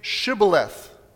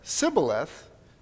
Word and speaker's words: Shibboleth: All right, say Shibboleth. And if he said Sibboleth, because he could not pronounce the word correctly, they seized him and killed him at the fords of the Jordan.